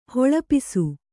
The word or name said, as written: ಹೊಳಪಿಸು